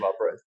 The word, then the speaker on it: Arbroath: Can I sidestep and ask you then about the Declaration of Arbroath? Have I pronounced that right? – That's right, yeah. And that's called the Declaration of Arbroath.